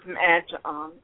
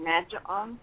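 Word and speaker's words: Mad Jon: Yeah, they can go to Facebook and type in Cooking with Mad John and Friends. Or if you just type in my chef name, Mad John, on Google, you'll find me.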